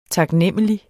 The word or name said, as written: taknemmelig